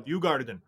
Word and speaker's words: Djurgården: How do you say the Djurgården? – Djurgården.